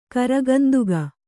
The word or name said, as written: ಕರಗಂದುಗ